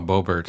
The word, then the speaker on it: Boebert: There was one party pooper, Lauren Boebert. Is that how you say it? – Oh, Boebert.